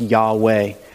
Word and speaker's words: Yahweh: and it is now pronounced Yahweh, which basically means God, creator, and Lord.